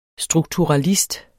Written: strukturalist

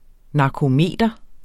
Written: narkometer

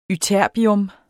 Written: ytterbium

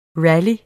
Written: rally

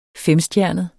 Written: femstjernet

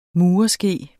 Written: murske

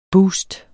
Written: boost

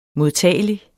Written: modtagelig

